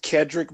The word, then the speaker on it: Kedrick: Just be sure to follow me on Instagram, the Kedrick Brown, at the Kedrick Brown, KEDRICK. You know, one of the funniest things ever is I always have people mispronounce my name. Kendrick, Keldrick, Kevin.